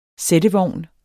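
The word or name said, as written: SÆTTEVOGNE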